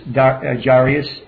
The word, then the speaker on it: Jairus: All right, and then as you progress in chapter number 9 over there, he raises Jairus's daughter. I can't pronounce it. Jairus is how you pronounce it.